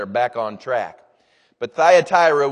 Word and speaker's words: Thyatira: And this is the church that's pronounced Thyatira. Thyatira.